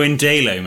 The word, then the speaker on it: Indalo: I just pronounce it as in Darlington, as in Indalo. Oh, Darlo man. Oh, Indalo man.